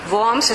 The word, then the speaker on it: Worms: Worms es una ciudad tremendamente próspera.